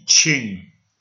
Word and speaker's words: Qing: Q-I-N-G, ching. It rhymes with R-I-N-G, ring. S-I-N-G, sing. D-I-N-G, ding. The old spelling was C-H-I-N-G, ching, but then the mainland Chinese authorities redid their spelling, so they're pronouncing it still as ching, but the spelling is Q-I-N-G.